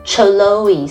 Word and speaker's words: Chloe: 但是它的拼法很特别，是 C-H-L-O-E，原来它的 C-H 是发 K 的音，所以不要念成 Chloe，是 Chloe。而且不知道为什么感觉叫做 Chloe 的人就很像是个正妹，很活泼，很聪明，很靓丽，很有活力这样的感觉。而且拉拉还查了一下哦， Chloe 在希腊语是指这个盛开的意思哦，所以你就像花朵一样美丽啊 Chloe。